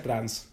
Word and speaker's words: trance: Claro, el juego del trance es por el trans que es que se escribe en inglés trance, pero se pronuncia trans.